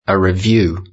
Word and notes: a review: British English

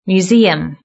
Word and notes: museum: British English

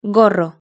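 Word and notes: gorro: Spanish (Spain)

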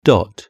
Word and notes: dot: British English

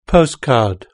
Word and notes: postcard: British English

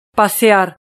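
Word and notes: pasear: European Spanish

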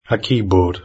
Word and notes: a keyboard: British English

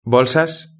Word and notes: bolsas: Spain Spanish

